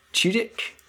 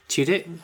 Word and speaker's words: Tudyk: The villain of the piece, or the ruler of Sugar Rush, is King Candy, voiced by Alan Tudyk. – Tudyk, I think is the pronunciation, but he's like a kind of...